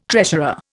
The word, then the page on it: treasurer: Indian